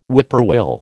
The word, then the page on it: whippoorwill: American English